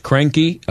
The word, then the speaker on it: Kroenke: The guy, the owner's name, I believe is pronounced Kroenke, Stan Kroenke, Kroenke.